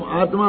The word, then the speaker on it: आत्मा: असलियत में क्या होता है दादा, कि उच्चारण में हम आत्मा आत्मा बोलते हैं ना, तो वास्तव में पूरे ही ता बोलते हैं। अपना सीख गया कि नहीं आत्मा में ता आधा होता है, तो लिखने में तो आधा लिख देते हैं, लेकिन जब बोलते हैं तो ता पूरा बोलते हैं।